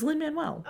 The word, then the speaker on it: Lin-Manuel: It's Lin-Manuel.